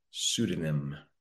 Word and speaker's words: pseudonym: Starts the P, but we pronounce that word pseudonym.